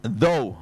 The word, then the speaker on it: though: 咁你點發音呢個字咧？Though 咁啊啱啦，th 啊伸條脷出嚟，though 係啱嘅。